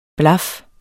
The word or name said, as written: blaf